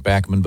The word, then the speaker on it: Bachman: Yeah, Randy, he pronounces it Bachman, but I guess they pronounce it Bachman in the United States, is a terrific guy and a great player.